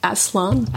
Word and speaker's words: Aslan: You call him Aslan? – Aslan.